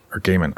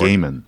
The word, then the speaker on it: Gaiman: Because blah blah blah, and apparently he and Gaiman actually, or Gaiman, I don't know how to pronounce his name, and I apologize for Gaiman. – It. Gaiman, Neil Gaiman.